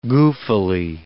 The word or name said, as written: goofily